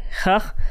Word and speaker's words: Chet: It's because in Hebrew, the word Hanukkah begins with the letter Chah, right? – Chah, which is spelled C-H-E-T. And since Chah doesn't have a letter in the English language, and they correlate it to J in some versions of Spanish.